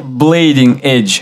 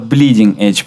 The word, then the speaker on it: bleeding edge: И Blading edge. Вот bleeding edge, правильно говорить.